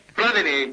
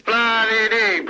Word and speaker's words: Platini: Platini. – Con l'accento sulla I, naturalmente. – Platini.